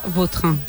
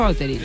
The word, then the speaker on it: Vautrin: La original francesa, Vautrin. – Vautrin. – Ahí pega un Vauterín. – A mí me gusta Vauterín.